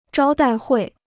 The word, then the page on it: 招待会: zhāo dài huì